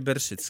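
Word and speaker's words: Bershidsky: To się tam jakoś inaczej jest napisane, ale czyta się Berszycki.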